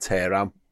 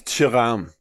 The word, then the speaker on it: Tehran: It's Tehran, but I'm Scouse, so it's Tehran.